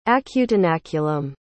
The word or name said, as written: acutenaculum